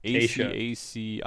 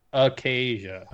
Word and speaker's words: acacia: How do you say that? What kind of branches? ACACIA? Kind of branches are those? – Acacia. – Asia? – Acacia. – Acacia? Acacia? You sure? – Sure.